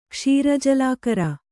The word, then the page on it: ಕ್ಷೀರಜಲಾಕರ: Kannada